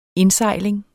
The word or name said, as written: indsejling